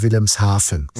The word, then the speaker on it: Wilhelmshaven: Das merkt man auch zum Beispiel bei Wilhelmshaven. Mhm. Das schreibt man auch mit V, aber man spricht es eben mit F aus.